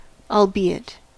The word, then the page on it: albeit: American English